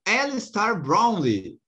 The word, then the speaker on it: Alistair Brownlee: Já estamos em clima de Olimpíada e vamos falar do Alistair Brownlee.